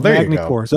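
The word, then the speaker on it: Magny-Cours: So it's Magny Cours.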